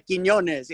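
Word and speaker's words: Quiñones: Yeah, Quinones.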